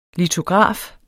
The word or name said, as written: litograf